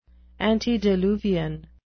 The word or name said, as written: antediluvian